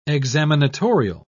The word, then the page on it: examinatorial: British English